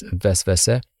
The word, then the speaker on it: vesvese: Waswasa is the Arabic word for this, and in Turkish it is pronounced as vesvese.